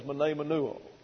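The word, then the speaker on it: mnemoneuō: Now, the biblical word for this process is menemenuo. Every room put it up. So here's how you spell it. M-N-E-M-O-N-E-U-O.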